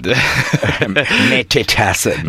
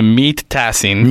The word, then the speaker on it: Mete Tasin: How would you pronounce it in American? Uh, in English? – Mete Tasin. No, usually they call me Meat Tasin. Meat.